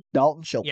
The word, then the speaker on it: Dalton Schultz: Dalton Schultz.